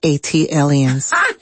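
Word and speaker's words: ATLiens: That is pronounced A-T-aliens. – Thank you. – Like Atlanta alien, A-T-aliens.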